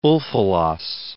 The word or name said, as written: Ulfilas